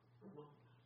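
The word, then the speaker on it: Rimmon: Ramon, how do you say that? What would you say? Ramon. All right, so I see how he does it now. You just got to say it with confidence.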